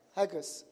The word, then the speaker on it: Haggai: How do you say Haggai in English? Haggai. Chapter 2. Huh? How do you say it? Haggai.